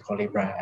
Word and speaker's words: Colibra: and that's the reason why we name it Colibra as well, because it rhymes with collaboration, although the real meaning of it is actually a hummingbird in English.